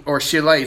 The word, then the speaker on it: Shelah: And Shelah is how you pronounce it.